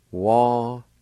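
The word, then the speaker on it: wadinvdi: Wa-di-na-di. Wa-di-na-di.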